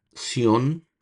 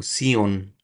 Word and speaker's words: Sion: Quiero hacerles aquí una pequeña aclaración cultural de leyendo las escrituras ahora, porque estoy leyendo estos comentarios más antiguos en tiempo, donde se le ponía Sión con acento en la O, Sión, y ahora ven las escrituras que no tiene ese acento en la O. No sé si la iglesia hizo esos pequeños cambios y entonces ya no hacemos el acentuar la O, ya de por eso menciono Sion. Versículo 41.